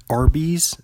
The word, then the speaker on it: Arby's: Did you know that Arby's is actually, like, if you say Arby's out loud, it's spelled A-R-B-Y-S, but if you say it out loud, it sounds like the letter R and the letter B, and do you know what that stands for?